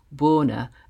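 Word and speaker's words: Bona: We talked about great Maverick bass players, Michele Ndegicello, Marcus Miller, Jaco Pastorius, Avishai Cohen and Richard Bonner, or Bonner, as he would pronounce it with his Sheffield accent, the emphasis on the O.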